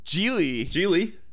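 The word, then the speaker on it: Gigli: Have you seen Jiggly? – And then he died. – Yeah, he died. Have you seen Jiggly? – Geely. That was Geely. – I think it's pronounced Geely, but it's spelled Jiggly.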